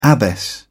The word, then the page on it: abbess: British English